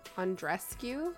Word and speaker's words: Andreescu: How do you say her last name? Andreescu? – Andreescu? yeah. Yeah.